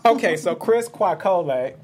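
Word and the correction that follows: Kwekowe: It's K-W-E-K-O-W-E. A 23-year-old. – Quake Coley.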